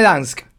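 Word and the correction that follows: Gdańsk: Muy bien, me eh, interesa. la busqué en internet. Vamos a ver si se puede escuchar. A ver. Gdansk. – Gdansk. Gdansk. – Bueno, ahí está, perfecto.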